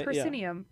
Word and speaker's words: proscenium: Proscenium. – Yeah. – That's wrong. Proscenium.